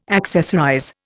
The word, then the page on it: accessorize: Indian